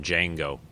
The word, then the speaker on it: Django: They named their son Django. – That's awesome. – And the D is silent.